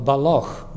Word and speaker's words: Balloch: called Balloch, B-A-L-L-O-C-H. If you pronounce it the other way, it's a major sin because that's Dumbartonshire and that's but this is Invernessshire.